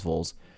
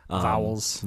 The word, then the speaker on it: Vowles: And this is a team that has, I think, really good management and leadership in James Vowles. Sorry. I believe it's pronounced Vowles. – Vowles.